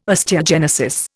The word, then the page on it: osteogenesis: Indian